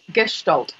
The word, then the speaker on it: Gestalt: and this is a word I find hard to pronounce, Gestalt? – Gestalt.